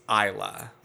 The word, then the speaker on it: Isla: Her in-law's backyard, but this her mother-in-law invited this her best friend to the party, and my niece's name is Isla, like mm-hmm. Isla Fisher. – I-S-L-A, yeah.